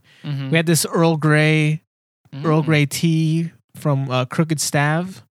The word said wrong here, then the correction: Crooked Stave: Is that how you pronounce that place? – Crooked Stave.